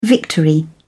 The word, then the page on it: victory: British English